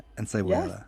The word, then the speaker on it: encebollada: Carne frita, and let me see if I can pronounce this correctly. – Yes. Yes, Encebollada. – you said it right. That's perfect.